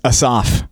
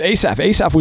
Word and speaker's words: Asaph: We have a new psalm writer here, and it's Asaph, is how my computer pronounced it, at least. – Yeah, I mean, that's the emphasis is on the last syllable, usually.